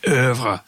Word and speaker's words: oeuvre: So the Munger Irv, or to give it the highbrow pronunciation, oeuvre.